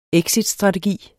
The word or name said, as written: exitstrategi